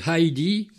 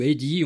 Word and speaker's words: Heidi: Il s'agit de Heidi, ou Eddie, on peut le prononcer comme on veut, H-E-I-D-I.